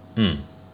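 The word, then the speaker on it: Ng: Eng is spelled N-G, but pronounced as I-N-G. It is a Cantonese last name, and Cantonese is a dialect of Chinese and it's a native language of Hong Kong. Eng is actually the English way to pronounce the last name, because the tone in Cantonese is not in the English language. The Cantonese way and the real way to pronounce my last name is M.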